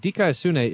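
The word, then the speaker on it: Dikaiosune (δικαιοσύνη): You do it with proper pronunciation, too, like you've just gotten back from Greece. Dikaiosune.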